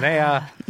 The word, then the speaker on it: Alinea: I said Alinea. It is Alinea. Allison was right.